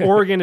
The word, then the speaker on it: Oregon: So I'm from Oregon, and we pronounce it differently than a lot of the rest of the country. So Oregon, you mean? – Yeah. So, and Oregon is correct.